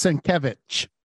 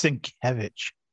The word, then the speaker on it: Sienkiewicz: It's pronounced Sienkiewicz. – Sienkiewicz. – It's Sienkiewicz.